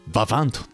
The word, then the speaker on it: VIVANT: うん、いや最初ね僕も分かんなかったんですけど、最後の「 T 読まんのかい」っていうね。 – ねえ、 Google 翻訳さんに発音させるとババントって言ってました。